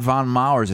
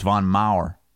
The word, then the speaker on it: Von Maur: But it's not Von Mars. It's not plural. It's Von Maur.